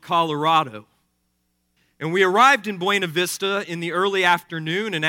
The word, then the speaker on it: Buena Vista: which the locals inexplicably pronounce as Buena Vista.